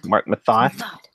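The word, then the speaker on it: Marc Methot: And of course, you see a lot of more defensemen, most notably Mark Meth. Well, I'm going to get this name wrong. Help me out, guys. Mark Mathot. – Yeah. – Thank you. Thank you.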